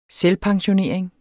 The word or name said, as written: selvpensionering